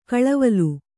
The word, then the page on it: ಕಳವಲು: kaḷavalu; Kannada